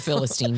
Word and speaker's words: Philistine: Philistinian? – He's a Philistine. You can just say it like that.